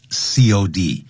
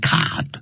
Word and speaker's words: COD: 好，然后就是 no problem, you'll get it, COD.、嗯、你会得到这个东西，但是 COD 是大写的，叫千万别念成 card，那是 Abbreviation 这个缩写字，就等于 cash on delivery，就是货到什么呢？